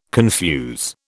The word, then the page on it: confuse: British English